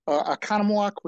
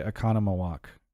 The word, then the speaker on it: Oconomowoc: Because I think I tried to say Oconomowoc. And you actually did pronounce it correct the first time. – Okay. Did I pronounce it correctly the second time? – Yes.